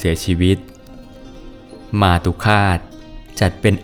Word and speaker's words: มาตุฆาต: สวัสดีครับพบกับรายการคำวัดวันนี้ขอเสนอคำว่ามาตุคาตมาตุคาตสะกดด้วยมอม้าสละอาต่อเต่าสละอุขอละคังสละอาต่อเต่าอ่านว่ามาตุคาต